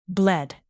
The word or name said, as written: bled